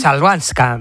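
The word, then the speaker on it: Chwalińska: Es complicada, ¿eh? Chabalinska. – ¿Eh? Chalvanska. – Chalvanska. – Maya Chalvanska. Maya Chalvanska.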